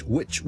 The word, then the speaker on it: which: 俾多几个例子大家，譬如 which w-h-i-c-h 哪一个 which，同埋一个巫婆咁咧 w-i-t-c-h which which which，同埋 which 有啲人会读成 which 嘅，so which is right，咁你可能就会听到咧某啲人就会读成 which。 It's right，唔需要一定学嘅呢个音，但你可以知道点解有啲人好咁样读。仲有啲咩例子咧？Where 亦都系 W H E R E，有啲人将会读成 where where，咁啊唔好当佢读错啦。